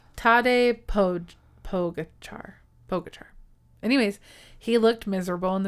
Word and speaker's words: Tadej Pogačar: So I called him Tadej Pogačar. And I looked it up on how to pronounce it in real life and how it's Tadej Pogačar.